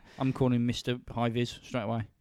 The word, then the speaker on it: Meiwes: And it's the story of Armin Meiwes or Meiwes. There's loads of different ways I've heard his name pronounced. But I'm going with Meiwes.